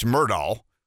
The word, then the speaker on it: Murdaugh: It seems to me that Alex Murdaugh, whose name is pronounced Alex Murdaugh, let me say that again, Alex Murdaugh, whose name is pronounced Alex Murdaugh, is one of these 90-10 people.